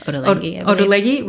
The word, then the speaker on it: Ottolenghi: Ottolenghi, am I pronouncing it correctly? – Ottolenghi, right? Mm-hmm.